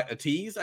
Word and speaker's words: Ateez: It's by Ateez, I believe is how it's pronounced. It's A-T-E-E-Z. Ateez.